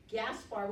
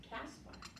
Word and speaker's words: Gaspar: Gaspar, which I actually think is pronounced Caspar. I think the G was pronounced as a C.